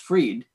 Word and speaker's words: Fried: well, first off, you introduced me by saying my last name is spelled Fried, but it's really pronounced Freed.